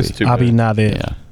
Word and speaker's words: Abinader: Abinader.